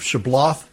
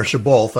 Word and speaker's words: Shibboleth: And, of course, there's the tricky words of Shibboleth and dropping, or Shibboleth, I'm sorry, I even mispronounced it.